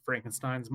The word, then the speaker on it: Frankenstein: Frankenstein's monster. – Kind of. It's pronounced uh, Frankenstein, by the way.